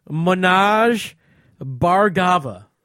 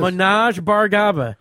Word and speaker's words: Manoj Bhargava: Manoj Bhargava. – Oh, who is this? – Manoj Bhargava.